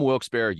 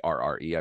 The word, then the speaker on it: Wilkes-Barre: If you're from Wilkes-Barre, you say it wrong. That's how you know you're from Wilkes-Barre, because it's Wilkes-Barre. But if you're from Wilkes-Barre, you say Wilkes-Barre.